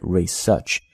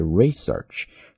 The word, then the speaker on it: research: 但是做名词的时候大家一定要注意，英式发音在读的时候呢，它的重音仍然是在第二个音节，读的还是 research，但是美式发音呢就把它迁移到了第一个音节，读的是 research。 So either research or research are both right，你自己去选一个啊。